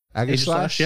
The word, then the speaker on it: Aegislash: Shiny Aegislash. Is how you say that? – Aegislash, yeah.